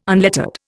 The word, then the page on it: unlettered: Indian